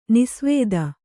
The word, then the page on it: ನಿಸೇವಿತ: Kannada